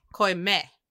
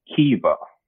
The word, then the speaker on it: Caoimhe: How do you say it? – Man, so this is like, if I didn't have this list in front of me, I'd be right with you guys, but this is Kiva.